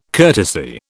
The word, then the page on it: courtesy: British English